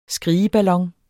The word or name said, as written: skrigeballon